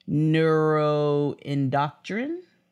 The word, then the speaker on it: neuroendocrine: Uh, in 2016, he was diagnosed with two forms of cancer renal and neuroendocrine.